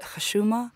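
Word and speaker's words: Khashuma (حشومة): One of them, and correct me if I pronounce this wrong, is it Khashuma? – Exactly. So, you pronounce it very well.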